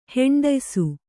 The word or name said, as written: ಹೆಂಡಯ್ಸು